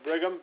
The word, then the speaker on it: Brigham: Yeah, Brigham, B-R-I-G-H-A-M. – Ragusa, and spell your last name just to make sure. – It's R-A-G-U-S-A.